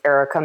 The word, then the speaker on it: Erica: It's Erica Mather or Erica underscore Mather.